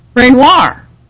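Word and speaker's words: Reynouard: His name is pronounced, his name is spelled R-E-Y-N-O-U-A-R-D. I always thought that name was just, would be just impossible for me to pronounce, because it seemed like it would have to be something like Ray Noir, but in some very Frenchy-sounding way. But it turns out he says it Renoir, and that's like the French Impressionist painter, Auguste Renoir.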